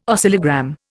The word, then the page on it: oscillogram: Indian